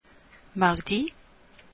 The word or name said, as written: mardi